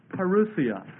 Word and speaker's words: parousia: And the word "coming" is the Greek word parousia. Parousia. And it's talking about the rapture here. Parousia is spelled P-A-R-O-U-S-I-A.